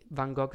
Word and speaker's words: Van Gogh: che tra l'altro per, mm, per i pochi Van Gogh si pronuncia Van Gogh.